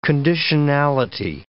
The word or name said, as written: conditionality